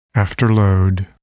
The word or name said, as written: afterload